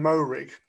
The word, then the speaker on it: Moehrig: Uh, Trevon Moehrig. Is that right? yeah, good, some good nods.